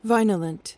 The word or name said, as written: vinolent